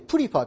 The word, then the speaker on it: prefer: 発音注意。 prefer と言います。 prefer。